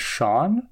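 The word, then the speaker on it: shone: There's a rhyme in here I really like, when she rhymes shown with known, because I pronounce that word S-H-O-N-E. I pronounce it the British way, which is Sean. So to me, those words do not rhyme.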